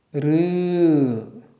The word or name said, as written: ṝ